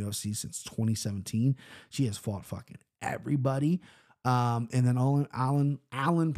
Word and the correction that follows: Aylin: Perez, Aylin Perez. Yeah, I think it's probably Aylin. Aylin Perez, there you go. You finally said the name right.